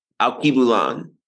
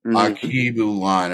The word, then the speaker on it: Alkebulan: Start with, how do you pronounce the title of it? – It's the name of the album is called Alkebulan. – Okay. And so since Alkebulan. – Alkebulan.